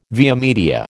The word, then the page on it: via media: American English